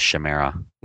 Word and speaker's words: Chimera: I like that he Twittered that the actual pronunciation is Chimera.